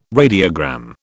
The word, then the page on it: radiogram: British English